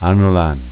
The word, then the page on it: Anolant: dialetto